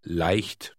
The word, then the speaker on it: Leicht: It contains the letter combination E-I. This is pronounced I, I. – Try reading Word 6 out loud. – Leicht.